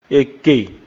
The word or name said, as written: Ekè